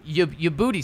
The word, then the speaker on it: Youboty: We're gonna go with John Ubudi is how I'm going to pronounce it. – So we're including practice squad as well in this. – Yes, yes. Yeah. John Ubudi. Ubudi? – Ubudi. So Y-O-U-B-O-T-Y.